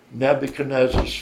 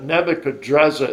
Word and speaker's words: Nebuchadrezzar: And the first king of the Second Babylonian Kingdom was a king named Nabopolassar, and that was Nebuchadnezzar's father. Now, if I slip, Nebuchadrezzar is really the way you say his name in Aramaic, but we'll call him Nebuchadnezzar. It's a little bit easier to work with, but he was a great king.